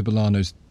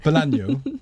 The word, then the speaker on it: Bolaño: Your pronunciation guru status has not been revoked. Bolaño. Bolaño. – Yes, okay.